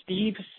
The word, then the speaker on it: Steve: It's Steve Simmons. – Okay. Can you spell that for me? – S-T-E-V-E.